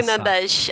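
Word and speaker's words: Lughnasadh: Se pronuncia assim, Lunasá. – Lugnadash. As pessoas aqui falam Lugnadash.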